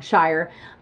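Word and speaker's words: Shirer: Schreier. Now, I'm a huge Priscilla Schreier fan. I'm pronouncing her name wrong, Shire.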